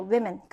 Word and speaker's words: women: women이에요, women. 그러니까 we라고 발음이 되고요. 여기서는 were라고 발음이 되는 거예요.